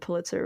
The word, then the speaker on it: Pulitzer: I know my boyfriend is trying to read all the Pulitzer, I said Pulitzer, right? I always say it yeah. – wrong. – Okay. Yeah, no, you said it right.